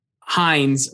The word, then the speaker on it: Hinds: And he phonetically spelled it out, and it's Kieran, and then Hines rhymes with Bynes.